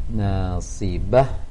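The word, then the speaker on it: nasibah (ناصبة): Amilatun nasibah, nasibah, buka sedikit lagi ataupun mengarah ke A, jangan mengarah ke E.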